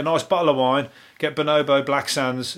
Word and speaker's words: Bonobo: I don't know how to say his name. Bonobo. Same as the monkey, isn't it? Bonobo.